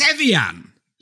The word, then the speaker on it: Evian: It's Evian.